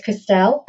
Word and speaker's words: Christelle: It's Christelle, as my mother would say it. If you're in Southeast London, the accent then sort of covers over, becomes Crystal. So I'll answer to anything.